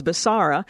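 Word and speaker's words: Becerra: National Institutes of Family and Life Advocates, which is NIFLA versus Basara, which, by the way, I confirmed the pronunciation, is as much a free speech case as an abortion case.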